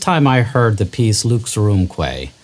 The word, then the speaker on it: Lux Aurumque: Well, first, your pronunciation of it is spectacular. – Oh, good. I got it. – Yeah, you got it perfectly. So your listeners know that Lux Aurumque, that crazy title, it's just Latin, and it means light and gold.